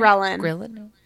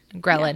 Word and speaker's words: ghrelin: And ghrelin, the hunger – am I saying that right? – Ghrelin. – Ghrelin, yeah.